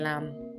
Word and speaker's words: lamb: The B is also silent in lamb. The Lamb of God upon the throne, not lamb. My Christian brothers and sisters, please take note.